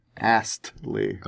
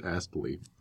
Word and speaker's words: Astley: Astley. – I believe it is Astley. – It rhymes with ghastly.